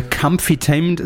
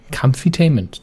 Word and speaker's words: Comfytainment: Nein, das nein, sie müssen es nur, sie dürfen das, müssen es richtig aussprechen. Kampf. Comfytainment. Das I ist die Betonung, weil es süß, ne? Comfy. Comfytainment.